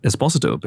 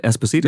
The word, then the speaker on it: Esposito: But which I've learned is now pronounced Esposito because of his appearance. – Oh my God, yeah, for years I've pronounced it Esposito.